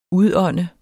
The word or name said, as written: udånde